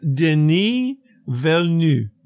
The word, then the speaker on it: Denis Villeneuve: Well, according to everything I've listened to recently, it's Denis Villeneuve. That's it. You don't pronounce the end of it.